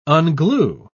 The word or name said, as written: unglue